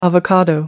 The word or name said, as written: avocado